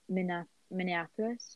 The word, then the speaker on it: Minneapolis: And yeah, and I've seen videos of the people protesting in Minneapolis, is that how you say it? – Yeah.